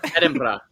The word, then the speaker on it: Edinburgh: I gotta say that right. Edinburgh. – Good. That's not Edinburgh. Good. – Edinburgh. Yeah. Edinburgh. – You gotta roll the R. – Yes, Edinburgh.